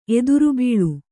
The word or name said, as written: ಎದುರ್ಬೀಳು